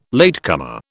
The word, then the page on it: latecomer: British English